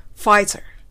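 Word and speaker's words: Pfizer: 日本語のふ、ファ、ファ、ファではなく、前歯を下唇につけて、ふ、ふ、ふ、ファイ、ファイ、ファイザーという風に発音してみたいと思います。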